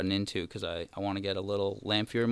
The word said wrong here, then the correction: lavalier: mic to do some like... – Oh, Lavalier. – How do you say it? Lavalier, yeah.